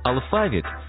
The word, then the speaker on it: алфавіт: Наголос у слові «алфавіт» на другому складі – основний у нашій мові, бо збігається із грецьким «алфавітон», від якого походить українська лексема.